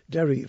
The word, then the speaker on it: Daoirí: Derry Farrell, which is spelt D-A-O-I-R-I, just in case you wondered.